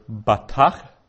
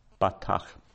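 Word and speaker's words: batach (בָּטַח): So there's a dot in the letter, and we pronounce that as a hard B, not as a soft V. Batach.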